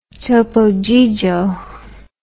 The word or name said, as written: Topo Gigio